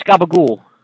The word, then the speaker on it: gabagool: But wait, what do you say? I mean, I've heard of capicola. – Yeah, it's gabagool. – Spell that, please. – G-A-B-A-G-O-O-L. Gabagool.